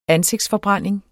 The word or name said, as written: ansigtsforbrænding